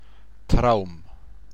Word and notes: souhait: French

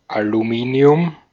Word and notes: Aluminium: /aluˈmiːni̯ʊm/

